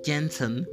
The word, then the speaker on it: Jensen: Según la doctora Janet Janet Jensen, lo iba a pronunciar como Janet Jackson, pero es Jensen, realmente estaba en una situación desesperada por algún tipo de solución.